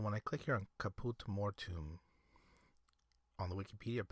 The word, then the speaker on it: Caput Mortuum: Next is Caput Mortuum. Caput Mortuum. What do you think that color looks like? It's spelled C-A-P-U-T space M-O-R-T-U-U-M.